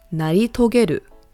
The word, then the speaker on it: 成し遂げる: Again, how to pronounce it is 成し遂げる.